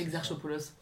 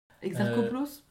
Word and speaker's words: Exarchopoulos: J'arrive jamais à prononcer. – Exarchopoulos. – Exarcho, très bien. Adèle Exarchopoulos. – Exarchopoulos Exarcho peut-être.